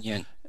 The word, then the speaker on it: Kuan Yin: Uh, is that the right pronunciation? – Yeah, Kuan, Kuan Yin.